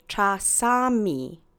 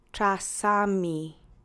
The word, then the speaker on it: czasami: Now that you have that fresh on your tongue, go ahead and say cha sa mi with me three times. Accent is on the middle syllable. Cha sa mi. Cha sa mi. Czasami.